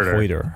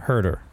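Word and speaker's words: Huerter: They drafted, I thought Herder, I'm sorry, I don't, is it Herder? I always say it wrong. Not Hoyter. Herder.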